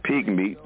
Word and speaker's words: Pigmeat: you can look up Pigmeat, just like it's spelled, P-I-G-M-E-A-T, Pigmeat Markham, M-A-R-K-H-A-M.